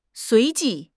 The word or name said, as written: 随即